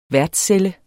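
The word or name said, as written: værtscelle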